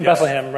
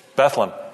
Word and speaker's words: Bethlehem: No, wait, you got to say it right, Steve. It's Bethlehem. – Bethlehem? – Bethlehem. Bethlehem. Yeah, not Bethlehem or Bethlehem, but it's Bethlehem. And there's a big debate. There's a big debate about what the pronunciation is and the correct pronunciation.